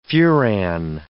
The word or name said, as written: furan